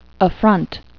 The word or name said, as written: affront